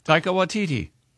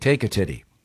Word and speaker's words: Taika Waititi: Taika Waititi. – Okay. I thought it was like take a titty.